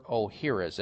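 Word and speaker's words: Ohhira's: Dr. O'Hara's, and O'Hara's is spelled O-H-H-I-R-A apostrophe S.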